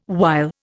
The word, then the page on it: while: Indian